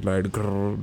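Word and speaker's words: Riot Grrrl: It was, you know, with the third wave of feminism and acts like Riot Girl, which was Riot Girl, that's how it's pronounced.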